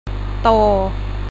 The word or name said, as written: โต